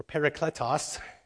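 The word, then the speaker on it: parakletos (παράκλητος): The Greek word behind that is paraclete, or parakletos, to pronounce it in Greek.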